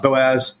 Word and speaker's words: Boaz: You have the two pillars, Joaquin and Baez. I'm not saying that right, but Boaz and Joaquin.